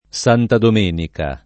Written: Santa Domenica

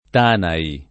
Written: Tanai

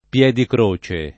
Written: Piedicroce

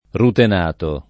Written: rutenato

